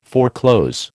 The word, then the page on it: foreclose: American English